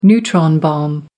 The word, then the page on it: neutron bomb: American English